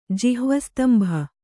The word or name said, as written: ಜಿಹ್ವಸ್ತಂಭ